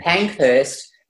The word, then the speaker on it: Pankhurst: And Pankhurst is my married name, which my husband was the great-great nephew of Emmeline Pankhurst, the suffragette.